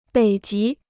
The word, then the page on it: 北极: běi jí